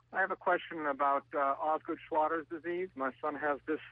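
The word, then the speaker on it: Osgood-Schlatter: Osgood Schlatter's disease. – Yes. – A very common problem. – Yes, it is, and you pronounced it very well.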